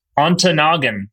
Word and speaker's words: Ontonagon: I got a call from Northern Michigan, a town in Ontonagon. Excuse me if I didn't pronounce it right, but I'm pretty sure I was close.